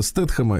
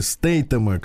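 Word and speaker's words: Statham: Джейсона Стэтхэма или Стейтема, как там, как mm-hmm. – по-разному можно произносить фамилию, на их манер, э, лучше, в принципе, такого вот человека не, не, никто не сыграет, да, такую роль.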